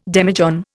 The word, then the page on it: demijohn: Indian